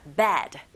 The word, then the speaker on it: bad: 이 침대, bed. 자, bad. 이렇게 발음하시고, 나쁜이란 뜻의 형용사는 bad, bad. 이렇게 발음하시면 됩니다. 철자 다르고 소리도 비슷한 것 같지만 달라요.